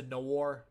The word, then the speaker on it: noir: It's a noir. How do you pronounce that? N-O-I-R. Noir.